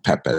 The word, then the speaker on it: Pepe: You know, it's like that West African pronunciation, really Ghana and Nigerian pronunciation of Pepe.